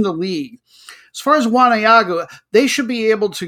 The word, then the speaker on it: Waneagu: Number B, Solomon Warriors versus number 3, going to pronounce it Wanayagu, W-A-N-E-A-G-U, United, Wanayagu United.